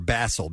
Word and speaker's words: basal: While basal tears, or basal, B-A-S-A-L is how it's spelled. So it's not like the herb. – It's more like the fish.